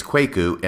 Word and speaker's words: Kwaku: Since the time I recorded that episode, I have been advised by a number of individuals that the correct pronunciation is Kwaku and not Kwaku.